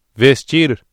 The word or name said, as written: vestir